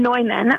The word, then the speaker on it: Neumann: Yes, we've got St. John Neumann, also called St. John Newman. It's sort of debated how we're going to choose to pronounce it in this country.